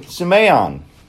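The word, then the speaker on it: semeion (σημεῖον): Semeion.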